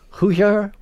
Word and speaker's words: Hugar: Hugar would be the more proper pronunciation? – Hugar.